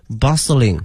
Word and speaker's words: bustling: 夹在这些高楼大厦之间图书馆哈，后面用的是 city bustling 哈，这个 bustling 哈非常熙熙攘攘哈，特别繁忙的一个词 bustling，同学们注意一下这个 t 呢是不发音的 bustling 哈。